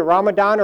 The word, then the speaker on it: Ramadan: Fasting during the month of Ramadan, unless they're from the Farsi-speaking world, it's Ramazan. And for some reason, Turks, who are neither Farsi-speaking or Arabic-speaking, took the Farsi pronunciation. So it's either Ramadan or Ramazan.